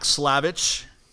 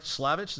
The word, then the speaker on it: Slavich: I want to talk to her husband, Zach Slavich. Am I pronouncing that correct? Slavich, is that right? – Yeah, that's correct.